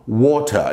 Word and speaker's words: water: Ah, os britânicos eles falam água assim, ó, water. Eles pronunciam o T, water. É claro que em outras regiões tem algumas outras pronúncias, mas a maioria dos britânicos pronunciam water, water. Já o americano é water, quer dizer, o T tem som de R, water, water.